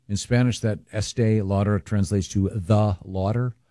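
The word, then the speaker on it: Estée Lauder: Estee Lauder, yes.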